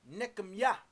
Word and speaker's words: Nehemiah: Nehemiah, you pronounce it in the African Hebrew language, Nekemiah.